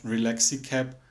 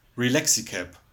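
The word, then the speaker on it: Relaxicab: Und Phoebe checkt nicht so ganz, und findet die Idee Relaxicap blöd, bis Rachel es dann irgendwann erklärt und sagt, okay, it's not Relaxicap, it's Relaxicap, like Taxicap. Also in einem Wort und schnell ausgesprochen.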